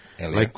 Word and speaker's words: Elia: Like when I was at school, the teachers always pronounce my name like Alaya. – Alaya. – Because it spells E-L-I-A. Yeah. So they said Alaya instead of Elia.